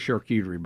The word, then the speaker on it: charcuterie: Look, I used to make fun of charcuterie boards, okay? – Yeah.